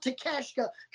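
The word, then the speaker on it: Takeshita: I know his last name's pronounced Takeshka because I butchered it the last time we talked about the guy because he wrestled Punk several weeks ago.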